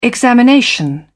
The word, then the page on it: examination: British English